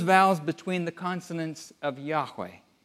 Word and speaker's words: Yahweh: Most scholars agree the name would have been pronounced Yahweh. But there were no vowels in ancient Hebrew.